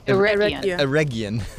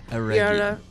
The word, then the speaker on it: Eregion: Eregion. Just think of a hard G. – Eregion.